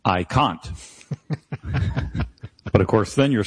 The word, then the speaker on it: Kant: No, it's not okay to say Kant. That's just wrong. It's done, but no, Kant is the correct pronunciation.